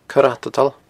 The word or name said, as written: coireatadail